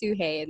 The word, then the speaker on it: Sugey: I always tell my mom, I was like, Mom, why'd you put that G in there? It's just, I always, yeah, all my friends, people I work with, the people I talk to, my email signature is always pronounced Sugey, and it's in parentheses. Yeah, my mom decided she wanted to put a G in there, and that's okay, but I always have to always correct my name.